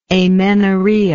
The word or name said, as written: amenorrhoeic